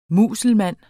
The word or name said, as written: muselmand